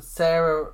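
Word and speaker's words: Sara: I think it's pronounced, it's like Sarah without the H, but Sarah Ramirez. – That's just Sarah, yeah.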